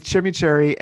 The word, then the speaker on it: chimichurri: We had skirt steak with chimichurri. Am I saying that right? Yeah, chimichurri.